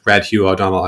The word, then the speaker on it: Red Hugh O'Donnell: and Red Hugh O'Donnell. I might have completely butchered the names there. – Dead right. – No, dead right. Okay, perfect. Yeah. I've done my research. – On the nose.